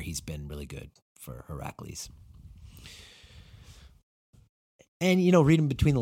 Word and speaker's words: Heracles: And he said his game really started to flower when he moved to the number 8 for Heracles, is apparently how you say it.